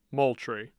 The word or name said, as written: Moultrie